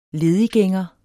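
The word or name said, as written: LEDIGGÆNGEREN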